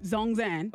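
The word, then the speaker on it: ZhongAn: Zong is Z-H-O-N-G and. – Zongzan? – Zongzan.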